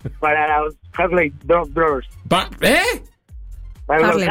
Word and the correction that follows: Harlem Globetrotters: Te tienes que meter a Harmon Hall, ¿eh? Harlem Globetrotters.